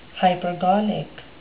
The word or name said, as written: hypergolic